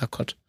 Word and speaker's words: Rokot: Also wenn du es richtig im Russischen aussprechen willst, dann ist es eine Rakot.